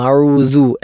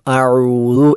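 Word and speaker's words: അഊദു (أعوذ): എന്നാൽ വേറെ ചില ആളുകൾ ദാലിനെ ദായി ഉച്ചരിക്കും, അഊസു അല്ലെങ്കിൽ അഊസു എന്ന് പറയാറുണ്ട്, അതും തെറ്റാണ്. അപ്പോൾ തെറ്റായതൊക്കെ നമ്മൾ മനസ്സിലാക്കി ശരിയായ രൂപത്തിൽ മാത്രം ഉച്ചരിക്കാൻ ശ്രദ്ധിക്കണം. അഊദു എന്നാണ് ശരിയായ ഉച്ചാരണം, ബാക്കിയെല്ലാം തെറ്റാണ്.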